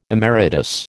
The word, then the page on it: emeritus: American English